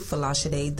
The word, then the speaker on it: Folasade: My first name is spelled F-O-L-A-S-A-D-E, Falachide the Accountant.